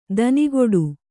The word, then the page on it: ದನಿಗೊಡು: Kannada